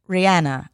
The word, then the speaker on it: Rihanna: And it was like, Rihanna.